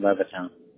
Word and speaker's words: Levittown: And the address that is attached to the card is the 710 Headley Avenue in Levington? – Yeah, Headley in Levittown. – Levittown. Sorry for the mispronunciation, brother. Right. – No, it's... H-E-A-D-L-E-Y? – Correct.